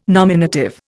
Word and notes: nominative: Indian

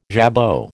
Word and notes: jabot: American English

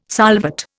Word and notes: solvate: Indian

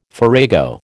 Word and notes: farrago: American English

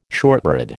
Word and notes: shortbread: American English

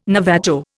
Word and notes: Navajo: Indian

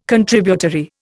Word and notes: Contributory: Indian

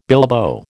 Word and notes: bilbo: American English

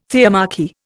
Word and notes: theomachy: Indian

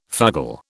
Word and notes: fugle: British English

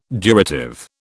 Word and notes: durative: British English

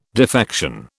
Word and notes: defection: British English

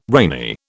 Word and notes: rainy: British English

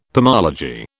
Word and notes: pomology: British English

